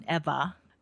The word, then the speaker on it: Ewa: So he didn't understand it because Eva is spelled E-W-A, but it's pronounced with a V sound, Eva.